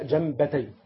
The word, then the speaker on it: جنبتي: شوف المثل ده الذي ضربه الله عز وجل، وعلى جنبتي الصراط، خلي بالك تنطق ازاي، جنبتي مش جنبتي، لا جنبتي في سكون على الياء لانها مثنى، جنبتي الصراط على الجنبين يعني سوران، في سور هنا في الطريق اللي انت ماشي فيه نفق يعني سور وسور.